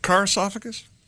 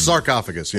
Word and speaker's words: sarcophagus: It's sarcophagus, not carcophagus? – It's sarcophagus, yeah.